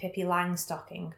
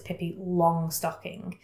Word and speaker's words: Pippi Longstocking: Me and my family have always pronounced it Pippi Langstocking, but I think people around here would know it's Pippi Longstocking. Don't ask me why it's been Langstocking. I don't know if it's the German pronunciation or what.